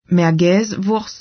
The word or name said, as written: Merguez-Wurst